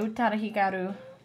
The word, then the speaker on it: Utada Hikaru: By Utada Hikaru. – See, she's nailing the pronunciation.